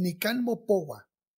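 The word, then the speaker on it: Nican Mopohua: El Nican Mopowa es el documento más importante sobre el mito guadalupano. ¿Sí lo pronuncié bien? Sí. – No sé ni cómo se pronuncia. Es Nican Mopowa.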